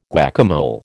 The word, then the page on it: guacamole: American English